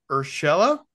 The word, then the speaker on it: Urshela: I'm hoping I get his name right. The Angels acquire Gio Urshela. Did I say this right? – Yep. – Yes.